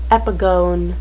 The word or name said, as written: epigone